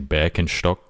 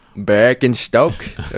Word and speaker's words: Birkenstock: Well, you know the German way of saying it is actually Birkenstock. – Birkenstock. Okay. Yes. That's good to know when I go to Germany. When I, yeah, nice Birkenstocks, friend. – Yeah, man, that's the right way to say it.